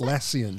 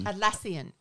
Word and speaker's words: Atlassian: Atlantis. Yes, exactly. How do you pronounce it? – Atlassian. – Atlassian.